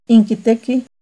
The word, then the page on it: Inquitequih: Náhuatl